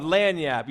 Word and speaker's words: lagniappe: Now, it's actually spelled L-A-G-N-I-A-P-P-E, but it's pronounced lagniap.